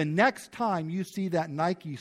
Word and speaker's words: Nike: The way we pronounce it in English is Nike. You ever see the brand Nike? That's what they name it for.